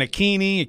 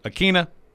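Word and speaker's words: Ikhine: By the way, to go back to an earlier discussion, I got confirmation from the Titans, Nick Westbrook Akina, just as we have been saying it. So anybody correct. – Saying Akini, Akine, and all that, Akine, wrong. Nick Westbrook Akina, that's the final word. – N-W-I-W-I-N.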